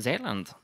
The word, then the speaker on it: Zeeland: Zeeland, actually, is how it's pronounced.